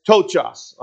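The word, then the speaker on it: tochos (πτωχός): It's P-T-O-C-H-O-S. I'm just going to go with tochos, all right? The P is silent.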